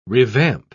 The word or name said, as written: revamp